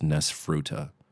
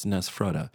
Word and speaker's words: Nesfruta: There's people in my life that believe it's pronounced Nesfruta, but it's spelled N-E-S-F-R-U-T-A. And that can't be right. It's Nesfrutta.